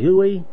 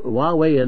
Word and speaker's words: Huawei: And it, to go to another further, you know, you've heard of H-U-A-W-E-I. How do you pronounce that? Huey? H-U-A-W-E-I. – Huawei.